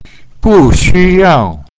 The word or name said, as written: bu4xu1yao4